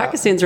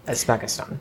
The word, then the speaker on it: Uzbekistan: Uzbekistan. – Mm-hmm. Uzbekistan, yeah. – I said it right. – Yeah, you got it.